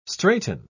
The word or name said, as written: straiten